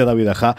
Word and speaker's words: David Aja: Siempre digo David Aja con leche y es David Aja, que al final se pronuncia David Aja, eh, que yo ya confieso que soy un fan absoluto.